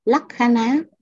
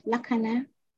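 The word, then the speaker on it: lakkhaṇa: lắc khá ná, chữ N có dấu chấm ở dưới, nó, lắc khá nó, nếu đọc đúng là phải cong cái lưỡi lên cả nhà, chữ N chị cho em là dấu chấm ở dưới.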